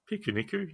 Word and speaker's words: Pikuniku: Am I saying that right? Pikuniku? – Pikuniku, yeah.